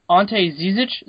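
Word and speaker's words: Ante Žižić: Then at 23, Boston takes, is it Ante Zizic? Is that the best way to pronounce it? – Yes.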